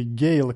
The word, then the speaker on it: Gade: Nils Wilhelm Gade ili Gale, kako se ovo prezime izgovara u njegovoj domovini, smatra se jednim od najznačajnijih danskih kompozitora 19.